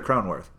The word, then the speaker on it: Cronenworth: Is that how do we pronounce it? Cronenworth or Cronenworth? – Cronenworth. Yeah.